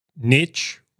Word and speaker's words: niche: Niche rhymes with rich for a reason.